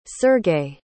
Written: sergei

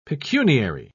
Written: pecuniary

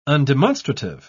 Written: undemonstrative

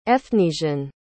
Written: efnisien

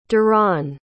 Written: duron

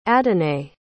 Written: adonai

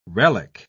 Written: relique

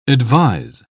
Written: advise

